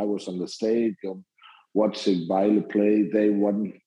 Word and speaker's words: Vejle: So, you know, following on from your sort of youth club that you signed up for, you moved to, now I will try this pronunciation, Via BK. – That was a good trial. In Denmark, we say Vejle. – Vejle. – Vejle, yeah.